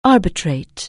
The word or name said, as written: arbitrate